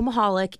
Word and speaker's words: Michalik: And my name's Nicole Michalik. Yes, the C is silent.